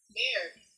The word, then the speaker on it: Mayer: Oscar, is it Oscar Meyer or Oscar Mayer? Mayer.